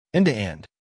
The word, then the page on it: end to end: American English